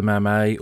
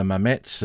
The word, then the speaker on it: Mametz: The first of those woods that we'll look at is Mametz Wood, Bois de Mamay, or Bois de Mametz, as the French call it. They use Mamay and Mametz as a pronunciation for the name of the village. Some accentuate the Z, others ignore it.